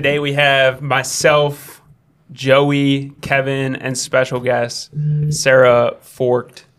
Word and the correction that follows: Forcht: Apparently it's Forch. Yes. – Forch? – Yeah. – Silent T? – Silent yes. T. – Okay, Sarah Forch.